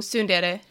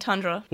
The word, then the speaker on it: Tsundere: That's yeah, it's she's mispronouncing Tsundere. She's saying Tundra.